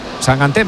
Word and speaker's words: Sangante: Sangante, se pronuncia sangante. – Yo pronuncio como me digáis. – Bueno, eso he leído yo, que dice que es sangante. – De hecho, me ha dicho, tú acuérdate de mangante.